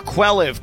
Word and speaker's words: Quelliv: And that's where Quelliv comes in. Let me spell it for you. Q-U-E-L-L-I-V. – One more time. – Q-U-E-L-L-I-V.